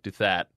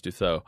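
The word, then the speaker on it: Douthat: Duthat, but it's not how you pronounce it. Ross Dutho.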